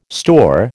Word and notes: store: American English

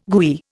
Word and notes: gooey: Indian

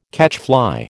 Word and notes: catchfly: American English

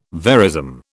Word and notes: verism: British English

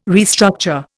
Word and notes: restructure: Indian

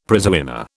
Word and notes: prizewinner: British English

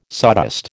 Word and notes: sawdust: American English

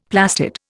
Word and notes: plastid: Indian

